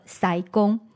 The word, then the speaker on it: 师公: 咱闽南话拢是读作师公。